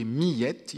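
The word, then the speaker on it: Miette: Millette, il faut prononcer en deux syllabes,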